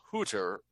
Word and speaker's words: Hutter: The founder of the Hutterites, Jacob Hutter, is how it'd be pronounced in German, established the Hutterite colonies on the basis of the Schlechtheim.